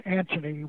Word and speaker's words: Anthony: Anthony Sutton, actually it's pronounced Anthony with no H, but I'm not used to saying it that way.